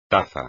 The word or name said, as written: taza